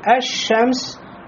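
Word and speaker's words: Аш-Шамс (الشمس): А когда говорим «Аш-Шамс», «лям» входит в «шин», и «шин» удваивается. Значит, «лям» здесь не произносится.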